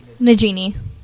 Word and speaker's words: Najimy: Kathy Najimy? – Najimy.